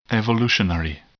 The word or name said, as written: evolutionary